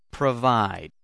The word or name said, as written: provide